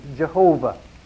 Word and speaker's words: Yahweh: The word "O Lord," the word for Lord there, is the word Yahweh, sometimes pronounced Jehovah.